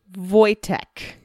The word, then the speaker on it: Wojtek: Wojtek. – Oh. – Okay. – Which is spelled with a W and a J. Okay. If you can imagine. W-O-J-T-E-K. – There's the Southern.